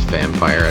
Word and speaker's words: Vampyr: I'm guessing it's pronounced Vampire. I don't think it would be Vampire or anything like that.